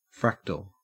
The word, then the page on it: fractal: Australian English